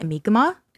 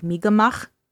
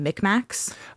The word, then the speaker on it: Mi'kmaq: When I was researching it, the how you spell Mi'kmaq is Mi'kmaq, but you pronounce it Mi'kmaq. – Oh, okay. – Like it's supposed to have like a more like a throaty sound, like Mi'kmaq.